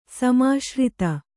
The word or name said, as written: ಸಮಾಶ್ರಿತ